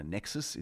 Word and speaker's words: NEXSS: But NASA has set up a thing called NEXSS, so you could kind of say, I don't know, Nexus is the way you pronounce it,